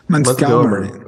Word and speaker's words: Montgomery: tá correta a minha pronúncia? Eu usaria um pouco mais de entonação do Montgomery. – Montgomery.